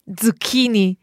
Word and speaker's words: zucchini: Zucchini. – Zucchini. – It's that ts sound at the beginning that will help you sound more Italian.